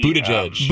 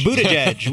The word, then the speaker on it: Buttigieg: Pete, Buttigieg. – Buttigieg.